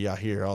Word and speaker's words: Yair: He definitely has got some dog in him, but I don't think he has the quickness to put away Yair. Yair. I say Yair all the time. Yair. Y-A-I-R. Y'all pronounce it how the hell y'all want to.